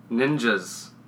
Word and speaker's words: ninjas: And don't forget to tune in to our next Top 5 episode, where we go over our Top 5 Ningas. – Ninjas. You're not getting paid if you don't say it right.